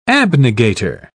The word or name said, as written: abnegator